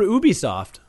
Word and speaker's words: Ubisoft: Well, good for Ubisoft.